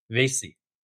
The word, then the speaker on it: Vasey: Vasey.